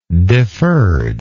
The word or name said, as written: deferred